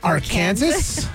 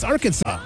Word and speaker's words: Arkansas: A lot of people are still wondering why it's not pronounced like Kansas. Because if you look at the word, it's AR and then Kansas. That's how Arkansas is spelled. Right. So shouldn't it be like Arkansas? – You would say that. – But it's Arkansas.